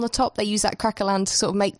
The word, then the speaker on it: craquelin: One of the other things that Tan did was create something called a craquelin. Is that right? Am I saying that right? – Yes, a craquelin.